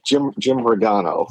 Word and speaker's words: Jim Rigano: Jim Ringo, how are you doing today? And thank you for coming on. – Yeah, thanks for having me, Samuel. Jim Rigano. – Rigano. I butchered it.